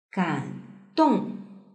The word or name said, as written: gandong